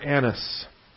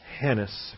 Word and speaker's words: Annas: Point one, only the Gospel of John records a preliminary hearing before Annas, or Hannas, if you want to put an H in front of it, or Hanas, if you want to really give it a Hebrew pronunciation.